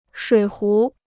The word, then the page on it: 水壶: shuǐ hú